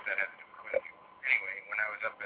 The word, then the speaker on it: Quincy: otherwise pronounced in other areas of the world where there's same spelling, but they pronounce it Quincy, like Quincy, Illinois. Up here it's Quincy, the Z, even though it's spelled the same.